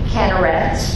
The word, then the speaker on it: Kinneret: It's the Sea of Kinneret. That's a Sephardic pronunciation. That would be an Israeli Spanish in the area of the Mediterranean Sea, pronunciation of the sea.